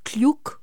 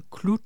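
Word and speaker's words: Cluj: Du er æresborger i en by, der hedder Kluj. Er det sådan, man udtaler det? – Ikke helt. – Ikke helt. – Det er jo et sprog med sådan nogle lidt mærkelige lyde. Den hedder Kluj.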